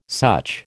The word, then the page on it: such: American English